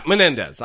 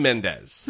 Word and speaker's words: Menendez: Yeah, Menendez. I said his name wrong, like a dumb shit. So anyway, did you? – Yeah, I think I called him Mendez.